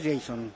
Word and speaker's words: Jason: 2 minutos y bueno, pues el tercer gol, Jason, que lo hemos preguntado, la pronunciación es Jason.